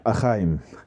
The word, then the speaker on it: Eheim: Y te tengo que dar una pequeña aclaración, y es que yo en Nascapers tengo filtros de Eheim, ¿cómo se pronunciaría en Alemania? Eheim.